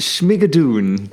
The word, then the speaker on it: Schmigadoon: So, und jetzt aber Schmiegadon. Oder ich weiß nicht, wie soll ich diese Serie aussprechen? Es klingt wie etwas aus einem, ich weiß nicht, Drachenuniversum. – Es heißt Schmigadoon. – Ah, Schmigadoon.